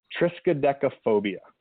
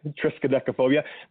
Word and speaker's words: Triskaidekaphobia: I actually watched a little YouTube to learn exactly how to say it correctly. So Triskaidekaphobia. – I'm scared of that name. – Yeah, Triskaidekaphobia.